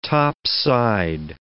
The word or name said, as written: topside